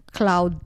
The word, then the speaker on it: Cloud: One time I remember, I bought the wave bench from Cloud Dayag. Oh, that's how you pronounce his name, hindi daw Claude.